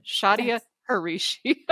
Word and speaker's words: Shadia Hrichi: Shadia Hrichi.